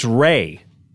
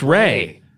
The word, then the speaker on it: Re: No, that it's pronounced Ray.